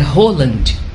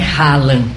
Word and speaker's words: Haaland: Aí ele olhou assim pra cara, como quem diz: nossa, porque o nome, a pronúncia certa é Roland. É. Não é Haaland, como está escrito e a gente fala.